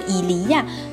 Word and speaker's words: il y a: 今天要教大家一个简称啊，我们都知道有是怎么说的，伊利亚，对不对？相当于英语里面的 there is there be 句型，伊利亚有。但是在口语里面呢，可能这个伊利亚读得太长了，我们会读成呀呀呀。